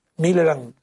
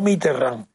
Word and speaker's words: Millerand: antes que Millerand, Millerand, lo digo bien, no Mitterrand, no conté, con L, L y L,